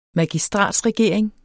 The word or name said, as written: magistratsregering